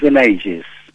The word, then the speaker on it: Benaiges: Bueno, en catalán es Benaiges.